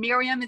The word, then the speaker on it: Myriam: And Miriam is spelled MYRIAM.